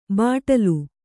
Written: ಬಾಟಲಿ

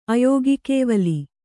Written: ಅಯೋಗಕೇವಲಿ

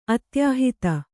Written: ಅತ್ಯಾಹಿತ

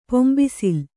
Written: ಪಂಬಸಲೆ

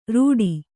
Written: ರೀಠೆ

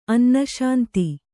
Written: ಅನ್ನಶಾಂತಿ

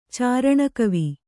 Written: ಚಾರಣಕವಿ

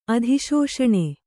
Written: ಅಧಿಶೋಷಣೆ

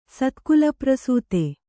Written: ಸತ್ಕುಲಪ್ರಸೂತ

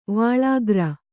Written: ವಾಳಿಕಾರ

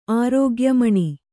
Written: ಆರೋಗ್ಯಮಣಿ

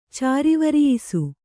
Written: ಚಾರಿವರಿಯಿಸು

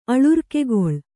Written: ಅಳುರ್ಕೆಗೊಳ್

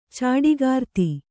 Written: ಚಾಡಿಗಾರ್ತಿ

